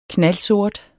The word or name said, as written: knaldsort